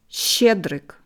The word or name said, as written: Schtschedryk